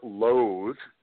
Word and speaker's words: loathe: But it seems to be being sort of abandoned for the verb loathe. Notice the difference in pronunciation. L-O-A-T-H-E.